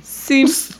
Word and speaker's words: seme: Seem. – Did you want to know that? Because I told you. – It's spelled S-E-M-E. Seem.